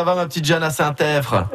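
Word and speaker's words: Saint-Epvre: Alors, Saint-Èvre, on prononce pas le P, hein, on est bien d'accord.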